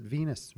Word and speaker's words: venous: We pronounce it Venus, V-E-N-O-U-S. Okay. But in any event, that would be V-E-I-N-O-U-S.